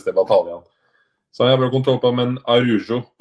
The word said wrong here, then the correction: Araujo: på eh, Barcelona. Det er vel rett uttalelse av navnet hans? Araujo. Araujo, sorry.